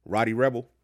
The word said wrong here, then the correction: Rowdy: Oh, Rowdy.